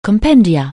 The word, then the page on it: compendium: anglais